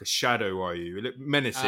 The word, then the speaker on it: Ryu: It's pronounced Ryu, really, but I've got to fucking put my own spin on it.